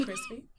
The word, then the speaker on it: Chrispy: And my name is Crispy. And that's spelled C-H-R-I-S-P-Y, also known as Lando Calrissian, also known as Sex, also known as Lil Uzi Squirt.